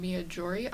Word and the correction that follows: Miyajima: Island? – Miyajima Island.